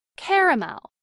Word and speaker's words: caramel: In much of the Northeast and parts of the South, this word is pronounced with 3 syllables. Caramel. In parts of the Appalachian Mountains and much of the Midwest, many Americans pronounce this word with 2 syllables, as in caramel.